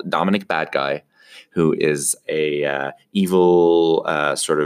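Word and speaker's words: Badguy: And his name is pronounced Dominic Badgie.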